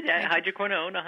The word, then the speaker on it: hydroquinone: Say it for me. Did I say it right? – Yeah, you did. Hydroquinone, an ingredient you definitely want to avoid.